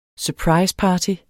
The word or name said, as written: surpriseparty